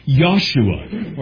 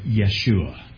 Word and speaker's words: Yeshua: Very few languages have that same soft J sound that we have in English. And so very few actually would pronounce it Joshua, but it's going to be Yahshua, or perhaps you have heard Yeshua.